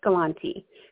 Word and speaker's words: Galanti: Some people say Galanti, and some people say Galanti. I like Galanti because it sounds like the word gallant, which means acting brave and heroic.